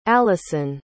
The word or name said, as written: alison